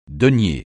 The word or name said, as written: denier